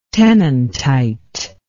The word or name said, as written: tennantite